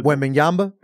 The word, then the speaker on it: Wembanyama: Wembanyama? – Yeah, there you go.